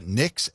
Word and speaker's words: Nyx: Nyx, N-Y-X, the goddess of night?